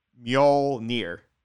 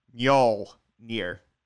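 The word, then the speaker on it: Mjölnir: How do you pronounce Thor's hammer? – Mjolnir. – Mjolnir? – Mjolnir. – Mjol, I'll never get it. I don't know. – Yeah. It's like M and then Yol, like Yol, except for like Yol, except without the U.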